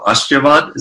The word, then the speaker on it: ashirvad: And, you know, people are always wanting to, in India, especially going up to a sadhu and gifting what they call ashirvad. Is that, did I pronounce that right? Blessings? – Yes, Prabhupada.